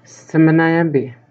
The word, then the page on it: aschiminaayaapii: Chisasibi